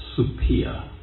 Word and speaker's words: Supia: her name would have been pronounced Supia, with, you know, a hard P sound.